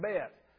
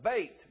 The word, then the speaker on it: Bet: That's, they call it bait. It rhymes with mate. I know it looks like bet, but it's bait. B-E-T, bait.